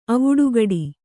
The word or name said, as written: ಆವುಟಕಟ್ಟು